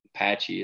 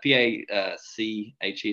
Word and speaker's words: Pache: Patchy, is that how you pronounce that guy? P A C H E. Is that Patchy or Pachy? – Pache. – Shay, see, I was wrong on both fronts.